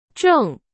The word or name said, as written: 正